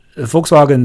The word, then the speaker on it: Volkswagen: 所以这个 Volkswagen 在真正的发音里面要注意，它这个第一个 V 读的其实是 F 的音，就是 F 的音，而不是 U 的音，对吧？是轻轻的，而不是浊的。所以这个词读出来之后就变成了 Volkswagen。